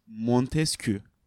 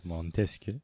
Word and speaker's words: Montesquieu: Montesquieu. Evet Montesquieu.